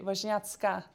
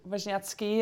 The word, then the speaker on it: Wozniacki: But if you do it in proper Polish, actually, the I in the end would be an A, and it would be Wozniacka. So that would be the proper pronunciation, because women in Poland always or usually end on an A, like Radwanska or Damochowska.